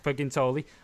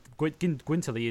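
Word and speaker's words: Guintoli: Gintoli, um, which actually he Gwintoli is the right way of pronouncing it. We all pronounce it wrong.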